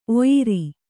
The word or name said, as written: ಓಯಾರ